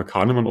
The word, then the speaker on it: Kahneman: Wir verstehen das jetzt im Sinn von Daniel Kahnemann, der Kahneman. Wir einigen uns mal, dass wir es auf immer Kahnemann aussprechen. – Ja, wir sind im Deutschen.